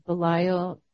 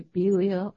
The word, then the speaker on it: Belial: I used to always pronounce it Belial. I recently heard you're supposed to say Belial.